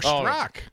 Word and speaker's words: Strzok: And it's written by a Peter Sturzok. He was the FBI guy. One of the FBI guys. – Oh, Peter Strzok. – Yeah. – Is that how it's pronounced? – Yeah, I believe so.